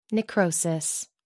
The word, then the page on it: necrosis: American English